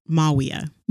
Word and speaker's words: Mawia: Maybe say it one more time with a little more emphasis on the W. You kind of... Mawia.